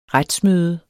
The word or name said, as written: retsmøde